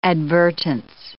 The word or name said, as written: advertence